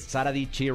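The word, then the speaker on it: Dietschy: Pero ella es Sara Dichi rhymes with Peachy, o sea, con, o sea, es como un juego de palabras con el durazno y también todas su merch son durazno.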